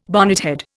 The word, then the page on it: bonnethead: Indian